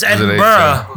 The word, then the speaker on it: Edinburgh: It's Edinburgh, by the way, is how you pronounce that.